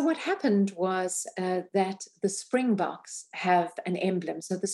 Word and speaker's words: Springboks: The story of Nelson Mandela, and we talked about this in the beginning, and the Springboks, did I pronounce that right? Springboks, yes. Rugby team, which was made into a movie with Matt Damon and Morgan Freeman, which was a seminal moment in your country's history.